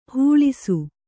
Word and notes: ಹಿಳಿಸು: hiḷisu

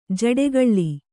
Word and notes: ಜಡೆಗೊಳ್: jaḍegoḷ

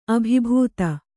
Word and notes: ಅಭಿಭೂತ: abhibhūta; Kannada

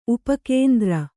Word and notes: ಉಪಕೇಂದ್ರ: upa kēndra

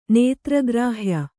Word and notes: ನೇತ್ರಗ್ರಾಹ್ಯ: kannada

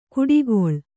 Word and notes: ಗುಡಿಗೊಳ್: guḍigūlf

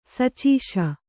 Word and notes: ಸುಚಾಸು: sucāsu

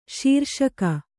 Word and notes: ಶೀರ್ಷಕ: śirṣaka

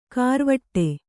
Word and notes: ಕಾರ್ವಟ್ಟೆ: kārvaṭṭe

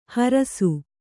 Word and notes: ಹರಸು: harasu

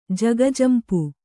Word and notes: ಜಗಜಂಪು: jaga jampu; kannada

